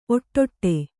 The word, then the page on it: ಒಡ್ಡು: oḍḍu